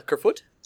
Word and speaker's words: Kerfoot: Is that how you say it? A Kerfoot? – Yeah, I think that's one you actually got right.